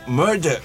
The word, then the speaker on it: Murdoch: La caída del Imperio Murdoch.